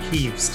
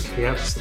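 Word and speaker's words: Kyiv: But it also means I'm on day four or five of takeaways so I've got some chicken Kieves tonight, as we're now calling them, no longer Kievs, because apparently that's the Russian pronunciation.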